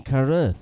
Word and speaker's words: Carruth: It is a 2004 sci-fi film written, directed, and starred in by Shane Carruth. I don't know if I'm saying that right. – Shane. – Right. Shane Carruth.